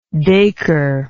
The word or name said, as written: daiker